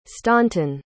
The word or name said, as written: staunton